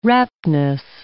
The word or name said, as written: raptness